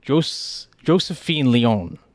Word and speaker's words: Josephine Leone: And we are interviewing Josephine Leone. Did I say that right? – That's right.